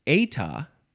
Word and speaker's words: eta: This is, it's eta. Eta, that's how it's pronounced. But in Britain, and I'm not in Britain, but in Britain, they pronounce it eta, eta, no, eta or eta, and it is spelled E-T-A.